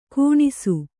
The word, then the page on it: ಗೊಣಸು: goṇasu